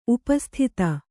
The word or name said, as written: ಉಪಸ್ಥಿತ